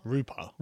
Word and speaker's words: Rupar: Aaron Rupar? – Rupar.